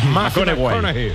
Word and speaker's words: McConaughey: Que hay que ir corrigiendo la pronunciación. – McConaughey. Mateo McConaughey.